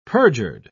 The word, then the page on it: perjured: British English